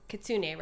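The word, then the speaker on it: Kitsuné: Speaking of your label, Kitsune, right? Like Kitsune, that's how you say it.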